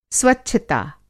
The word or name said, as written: स्वच्छता